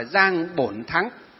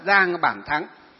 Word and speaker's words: Giang Bản Thắng: Ở bên Nhật Bản có một vị tiến sĩ, đó là tiến sĩ Giang Bản Thắng, ở tiếng miền Nam gọi là Giang Bổn Thắng, miền Bắc chúng ta đọc là Giang Bản Thắng.